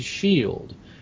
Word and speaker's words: Rothschild: Originally named Bauer, this family of financiers took a new name from the Red Shield, which adorned their house in the Jewish section of Frankfurt, and thus became Rothschild. Commonly, the last syllable is pronounced as if it were child. It is Shield. Shield.